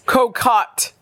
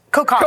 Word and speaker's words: cocotte: How do you pronounce it? Coquette. – Is a coquette? – Cocotte. – Cocotte.